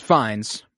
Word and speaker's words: Fiennes: Fascinating pronunciation that you would never, ever guess. And Fiennes. It's not... It's Fiennes, but the first name is the one that's bizarre.